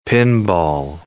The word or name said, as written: pinball